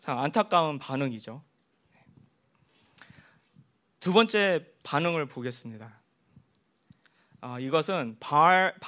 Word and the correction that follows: 바알: 알 바알, 발음이 힘드네, 발. 네, 바알 선지자들에 대한 바알의 응답.